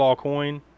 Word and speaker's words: Aucoin: I guess that was basically Kiss's production company, and their manager at the time was, I think his name was Bill All Coin. I think that's how you say his name, A-U-C-O-I-N.